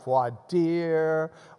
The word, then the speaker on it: voir dire: Other places of the U.S. pronounce it like the French phrase it is, with like voir dire or things like that. But here in Texas, law school, we just look voir dire. It's the way it's spelled, V-O-I-R-D-I-R-E, voir dire.